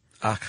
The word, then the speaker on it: Akh: The, Ka, the Ba, and the Ak. – Ak. – Yeah. – A-K-H.